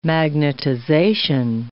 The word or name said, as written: magnetization